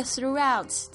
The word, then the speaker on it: throughout: 好，那这边这个 throughout 这边要特别注意，这个 GH 在这边是不发音的。